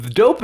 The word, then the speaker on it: Dope: It's spelled dough like D-O-U-G-H-P, but it's pronounced dope.